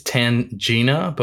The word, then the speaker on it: Tangina: At this point, because Tangia? – No, I think it's Tangina, but we never get the pronunciation, but it's T-A-N-G-I-N-A. So say Tangina.